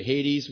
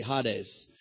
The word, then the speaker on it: Hades: Hades, we like to say, but it's actually Hades.